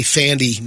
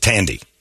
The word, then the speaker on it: Thandie: I'd always say Thandi Newton. – It's Tandy. – It's not Thandi Newton.